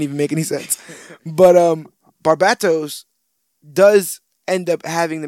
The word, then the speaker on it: Barbatos: Am I saying that right? Barbatos? – Yes, yes. – I feel like the bat needs to be emphasized. Barbatos?